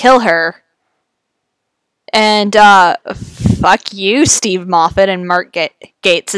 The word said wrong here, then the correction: Gatiss: is for that. – Gatiss, yeah. Maybe I'll make it a thing and always try to mispronounce Mark Gatiss's name.